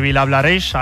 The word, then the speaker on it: Vilablareix: Gracias, Santi, desde Vilablareix. Ahora sí que lo he dicho bien.